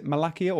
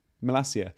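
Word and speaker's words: Malacia: Is it Malakia or Malasia? – Malasia.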